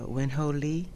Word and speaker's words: Wen Ho Lee: Lee's situation also reminds me of the plight of, is it pronounced Win Ho Lee? – Yes, that's right.